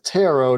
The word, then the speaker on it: Turo: And we've checked the pronunciation. It is Taro, despite it being spelled T-U-R-O. So in case there's any confusion, T-U-R-O, but not pronounced like burrow.